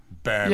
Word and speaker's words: Boehm: When I go back, my last name's Boehm. It was B-O umlaut. With the umlaut, yeah. H-M only. Bam.